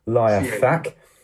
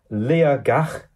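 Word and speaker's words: Liathach: it's spelt L I A T H A C K, so I would call it Liafac. But the T is pronounced as a G.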